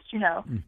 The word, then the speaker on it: Juneau: Am I saying it right? Is it Juneau or Juneau? – Yep, it's Denise Juneau.